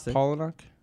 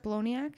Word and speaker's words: Palahniuk: Do you like Chuck Palahniuk? – No, Chuck Palahniuk.